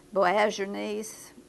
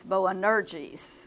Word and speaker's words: Boanerges: Boaz's is the way I've pronounced it. And then I heard Dr. Stephen Lawson pronounce it Boanerges. So I went back and took a look at it, and sure enough, I had the N in the wrong place. It's Boanerges.